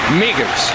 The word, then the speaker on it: Migos: Migos. – In the context, doesn't that whistle at the end sound really sad? – In 5, 4, 3, 2. And he mispronounced it. It's Migos. It's an O.